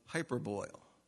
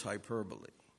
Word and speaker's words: hyperbole: Or maybe you pronounce it hyperboil. But I'm pretty sure it's hyperbole.